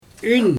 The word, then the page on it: yn: Xyzyl